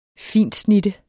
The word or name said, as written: fintsnitte